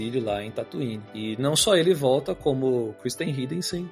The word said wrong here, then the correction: Hayden Christensen: Hayden Christensen.